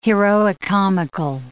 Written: heroicomical

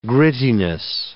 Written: grittiness

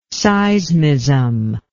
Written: seismism

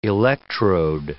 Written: electrode

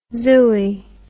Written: zooie

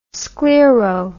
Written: sclero

